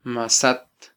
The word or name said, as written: masat